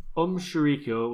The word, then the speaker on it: Aum Shinrikyo: Yeah, interestingly enough, the last surviving member of, how do you say it, Aum Shinrikyo. – Aum Shinrikyo was executed last year, 6th of July. – Aum Shinrikyo. – Aum Shinrikyo. – Yeah. – Was executed just last year. – Aum Shinrikyo.